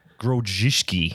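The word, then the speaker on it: Grodziskie: Am I pronouncing that correctly? – Grodziski. – Grodziski? Yeah, okay.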